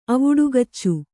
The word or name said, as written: ಅವುಡುಗಚ್ಚು